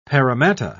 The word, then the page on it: paramatta: British English